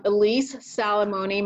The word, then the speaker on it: Elis Salamone: So they can find me on LinkedIn, um, Elis Salamone.